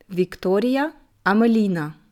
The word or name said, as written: Victoria Amelina